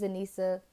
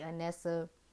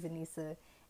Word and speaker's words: Anesa: I don't really think my name is too hard to pronounce, but people like to call me Anessa. If you want to say Anessa, cool, but that's not my name. My name is Anissa, A-N-E-S-A.